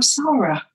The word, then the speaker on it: Sara: I'm Sara.